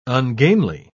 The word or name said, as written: ungainly